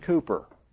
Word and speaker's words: Cowper: In the hymn books, you'll see that his name, the English spelling of that is C-O-W-P-E-R, but it's pronounced Cooper.